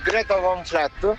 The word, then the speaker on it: Greta Van Fleet: Niente, per artisti, io devo essere sincero, da un anno a questa parte ho scoperto Greta Van Fleet. Sono i suoi, si pronuncia così.